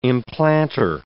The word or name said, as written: implanter